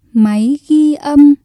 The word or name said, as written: máy ghi âm